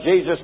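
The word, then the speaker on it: Jesús: They call it Jesus in the Spanish language, but it's spelled J-E-S-U-S. And to them, it's Jesus. That's the way they pronounce Jesus, because a J in Spanish is pronounced like an H, and an E like a an A. So they say Jesus instead of Jesus.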